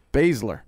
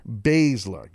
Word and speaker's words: Baszler: I keep saying that poor girl's name wrong. I don't mean it. I just say it one more time for me. – Baszler. – Baszler. Got it.